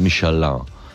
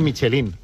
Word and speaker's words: Michelin: Michelin. A ver, aquí en la península ibérica hacemos Michelin.